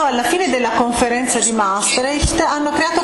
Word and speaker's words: Maastricht: E qui a questo punto allora, e devo pronunciarlo in maniera corretta, dobbiamo ritornare a Maastricht, l'ho pronunciato bene, Maastricht, dobbiamo ritornare a Maastricht.